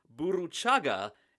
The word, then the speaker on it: Burruchaga: But it would be a goal by Jorge Burruchaga at the 83-minute mark that would seal it for Argentina.